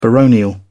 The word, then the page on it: baronial: British English